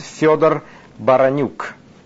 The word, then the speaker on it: Fyodor Baranyuk: Let me say my name in Russian in a perfect way, how we say it in Russia. My name is Fyodor Baranyuk, and I'm from the city of Moscow.